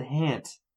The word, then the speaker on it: haunt: Haunt. Verb. Apparently you can also pronounce it hant.